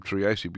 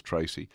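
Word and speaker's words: Treacy: I keep on calling him Triasi because that's how it's spelt and that's how I believe it should be pronounced, but everybody just goes Tracy.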